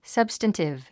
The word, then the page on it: substantive: sʌ́bstəntiv; サブスタンティブ